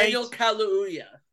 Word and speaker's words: Kaluuya: Daniel Kaluuya, I think his name is, right? – Daniel Kaluuya, yeah. – Okay, that's how you pronounce it.